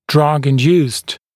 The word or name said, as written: drug-induced